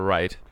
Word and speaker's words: Wright: Und ja, wir wollen darüber reden, was passiert wäre, wenn Peter, White, äh, Peter Wright, ich kann den Namen nicht aussprechen, Peter Wright, ähm, ich wollte gerade das R rollen, so wie.